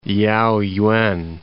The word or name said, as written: Liaoyuan